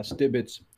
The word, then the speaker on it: Stibits: the way to pronounce it is actually uh, Stibits. Uh, so S T I B I T S. It's a platform. Yeah, Stibits.